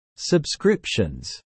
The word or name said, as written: subscriptions